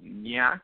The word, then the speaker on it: Nyack: It's off the Hudson River, and the part of the area is called Nyack, I believe is how you say it, N-Y-A-C-K.